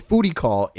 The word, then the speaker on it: foodie call: A foodie call is going out on a date for free food. – I just got that. it rhymes with booty call.